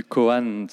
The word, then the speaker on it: koan: Alors, parle-nous du principe du koan. Je ne sais pas si je le prononce bien, mais... – Oui, euh, très bien.